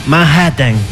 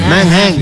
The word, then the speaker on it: Manhattan: Manhattan. – Sí. Cuando hay dos tés, la una tés muda.